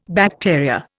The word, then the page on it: bacteria: Indian